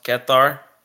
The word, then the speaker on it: Qatar: Fact checked, it's pronounced Qatar. We don't have two of those sounds in English. – Okay, Google. – We don't have two of those sounds in the English language.